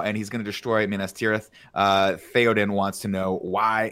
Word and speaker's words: Minas Tirith: Did I say it right, Andy? – Minas Tirith. – Minas Tirith. – Yeah. – Ath.